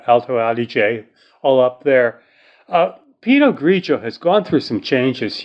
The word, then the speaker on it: Pinot Grigio: and I think we're now actually pronouncing that correctly. We were schooled on that. Pinot Grigio, whose primarily region to produce it is definitely the nation of Italy.